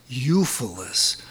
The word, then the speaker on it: Ulfilas: U-L-F-I-L-A-S, Euphilus. Sometimes in the Germanic countries, it's preceded with a W, which they don't pronounce.